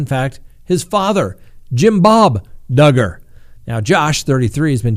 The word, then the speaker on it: Duggar: Josh Duggar, rhymes with hugger,